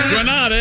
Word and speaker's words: Granada: I'm going to rhyme... – Camp Granada. – Granada and father. – Pronounce it like that.